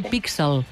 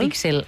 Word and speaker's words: píxel: O sigui, és important pronunciar-ho píxel. – Exacte, píxel. – No píxel, amb la pronúncia castellana, eh? – Píxel, perquè l'accent el trobarem a la I i llavors la E es queda així.